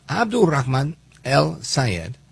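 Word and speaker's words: Abdulrahman El-Sayed: And I say it like that again, at his request. So, Abdulrahman El Sayed, he says he's not moderating his position on Israel.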